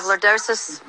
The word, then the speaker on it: lordosis: And he said they're in okay places, but I have, how you pronounce it, lordosis? – Yes. – I have lordosis.